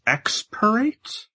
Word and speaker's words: Extirpate: Next card is, actually, I'm not entirely sure how to pronounce this card, I'll be honest, Extirpate? – Yeah.